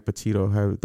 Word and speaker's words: Petito: Gabby Petito. That's how you say her name. Her last name.